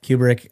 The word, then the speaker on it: Kubrick: Yeah, am I pronouncing that correctly? Kubrick. Yep. Kubrick.